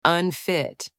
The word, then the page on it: unfit: anglais